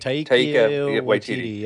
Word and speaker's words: Taika Waititi: I can never pronounce his name, but yeah, you know, they talk about, click on Taika Waititi. – Taika Waititi, yeah.